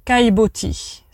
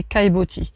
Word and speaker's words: caillebotis: Et ça, ça s'appelle les kaiboti. Ça s'écrit un petit peu bizarrement. Euh, c'est les kaiboti.